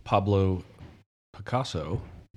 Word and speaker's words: Pablo Picasso: So Pablo Picasso, I said it right the first time. – Yeah, you did.